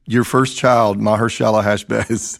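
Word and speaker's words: Maher-shalal-hash-baz: Then the Lord said to me, take a large tablet and write on it in common characters belonging to, I'll let you try and pronounce it. – Maharshalal Hashbaz. – That's great.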